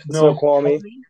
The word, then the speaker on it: Snoqualmie: Snoqualmie.